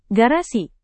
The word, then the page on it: garasi: indonesian